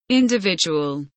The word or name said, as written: individual